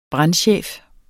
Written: brandchef

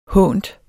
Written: hånt

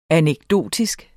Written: anekdotisk

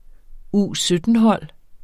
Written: U17-hold

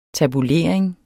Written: tabulering

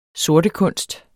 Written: sortekunst

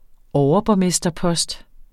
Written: overborgmesterpost

